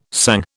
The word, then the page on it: sang: British English